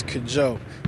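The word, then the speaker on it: Kwadzo: On paper, it's K-W-A-D-Z-O. It's Kajoe. And the last name is A-H-E-L-E-G-B-E, Ahelegbu.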